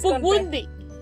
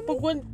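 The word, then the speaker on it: Pukwudgie: Es Pukwun. Ok, es que de verdad está es difícil. Con P, es Pukwundi. – Pukwundi.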